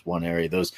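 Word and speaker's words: Nwaneri: It starts with an N, but it's NW. So it's Wanneri.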